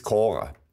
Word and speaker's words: Kora: Also Schwäbisch, richtig urschwäbisch ausgesprochen, das Kora. Das Kora war das alte Getreide der Hochfläche mit mageren Flächen, mit mageren Böden, ging der Dinkel hervorragend.